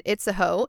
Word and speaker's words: Itzehoe: I-T-Z-E-H-O-E.